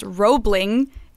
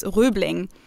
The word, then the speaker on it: Roebling: His name, well, he's pronounced, the bridge is pronounced Roebling in yeah. English, Roebling Bridge. But of course, it was OE. So in German, it would be pronounced Rübling.